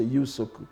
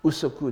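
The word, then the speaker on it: yūsoku (有職): En cela consiste la science du protocole, yūsoku, qu'on traduit aussi parfois par yūsoku, ou bien, il y a encore des gens qui disent yūsoku, mais donc, la plupart du temps, la prononciation la plus standardisée, enfin, si on peut dire, c'est yūsoku. Usoku est aussi possible.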